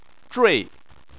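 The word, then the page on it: zhui: tone 4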